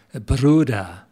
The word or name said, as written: Bruadar